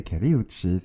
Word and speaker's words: Kali Uchis: Et Caliuchis. J'ai bien prononcé.